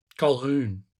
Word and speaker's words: Colquhoun: Colquhoun. – Oh, well done. – Yeah. – Great work. – Been working on that. I called my friend Macaulay Culkin and he explained to me it's Colquhoun.